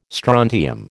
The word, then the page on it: strontium: American English